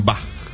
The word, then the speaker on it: Bach: No es Bach, es Bach. Así, no podía pronunciar el H y me decía a mí cómo tenía que decir.